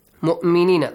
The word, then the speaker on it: mu'minina: We have to pronounce the hamza. Mu'minina. Look, mu'minina. Waw on the top of waw, you have hamza.